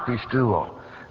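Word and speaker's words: pisteuō: In the Greek language, saving faith is the verb pistuo, which is pronounced pistuo.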